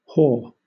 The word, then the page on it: haw: British English